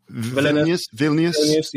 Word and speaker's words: Vilnius: Yeah, I think I don't know how to pronounce this name, but I think it's like Vilnius, right? – Vilnius.